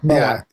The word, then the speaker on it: Moai: How do you pronounce it? Moai? – Moai.